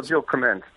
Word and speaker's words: Krementz: it's Jill Krementz. Is that how you pronounce it? – Well, Jill Krementz. – Krementz. Okay.